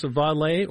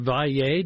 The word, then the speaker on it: Valle: Now, that's Marcos Valle, or Valle, depending upon, I guess, how you pronounce the double L's, whether you like the Portuguese or the Spanish version.